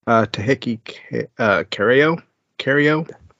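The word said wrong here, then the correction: Tcheky Karyo: Jackie Cairo.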